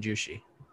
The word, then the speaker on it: Jushi: It's really Jushi. I'm just I don't know. Spelled uh, a. it's spelled J U S H I Holdings.